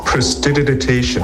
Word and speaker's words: prestidigitation: It's prestidigitation. – Okay, I said it wrong.